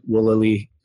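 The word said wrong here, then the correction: Zulily: Let's just talk about my mispronunciation. I said Zoot and Woolily. I meant Woot and Zoolily, a Groupon, things of that nature.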